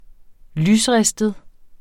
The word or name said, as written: lysristet